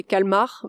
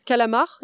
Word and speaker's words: calmar: Le vrai terme c'est calmar, mais c'est un abus de langage. C'est plus facile à dire calamar, c'est juste un aspect linguistique. Euh, les gens de manière générale pour simplifier la prononciation, disent calamar.